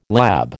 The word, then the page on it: lab: American English